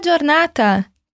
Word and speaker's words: giornata: O G e o I juntos em italiano se leem di, como dia. Giornata. – Então, como eu falo tenha um bom dia? Buona giornata.